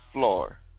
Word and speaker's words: fluor: But anyway, yeah, so we're going to go into the definition of FLUR, which is spelled FLUOR.